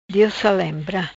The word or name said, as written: Diosalembra